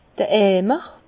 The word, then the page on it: d'r Eimer: Bas-Rhin; Herrlisheim